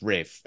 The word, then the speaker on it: Ralph: spelled Ralph, but it's pronounced Rafe.